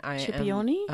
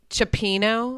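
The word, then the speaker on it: cioppino: Chipioni? – A rube. It's Chipino.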